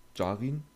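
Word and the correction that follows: Djarin: Wie, wie, wie sprichst du den aus? – Den Jaren. – Den Jaren, ah, ja. Ich habe zu deutsch ausgesprochen.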